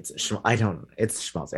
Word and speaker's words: schmaltz: When we last left off, we talked about like smalt. How do you say this word? – Schmaltz. Yeah, it's a sh sound.